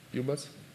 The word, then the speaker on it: ubus: It's the right pronunciation, UBUS.